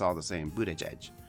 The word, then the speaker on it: Buttigieg: Well, I thought it was Buttigieg, and I thought, what a great name for a gay man, right? Buttigieg. But it's not. It is... Oh, here it is. Pete. It's pronounced, and I'm going to go through a list of these. Pete... Buttigieg. He's from Buttigieg. – He said Buttigieg.